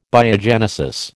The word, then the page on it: Biogenesis: American English